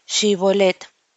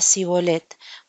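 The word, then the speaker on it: Shibboleth: Si él respondía no, entonces le decían, ahora pues di Shibolet. Y él, y él decía Sibolet, porque no podía pronunciarlo correctamente.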